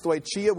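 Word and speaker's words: stoicheia: It's the word stoichia. That's how I'll pronounce it, stoichia.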